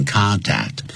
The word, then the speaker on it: contact: 不管是 contact 这个词当动词或者是名词都要念成 contact。